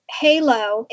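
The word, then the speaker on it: Heilo: So the name of the company is Halo, but it's spelt different. It's H-E-I-L-O. And we have an accent over the E to make it an A sound.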